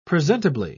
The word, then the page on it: presentably: British English